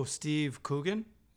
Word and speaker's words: Steve Coogan: Oh, Steve Coogan. Is that how you pronounce his name? – Oh, yeah.